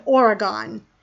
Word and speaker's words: Oregon: Washington, California, and Oregon. So I know some people say Oregon.